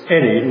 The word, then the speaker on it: anie: And any is spelled A-N-I-E, but it's any, not any.